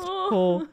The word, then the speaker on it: Hoare: H-O-A-R-E. Pronounced oh.